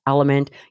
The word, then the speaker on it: LMNT: It is spelled L-M-N-T and pronounced Element.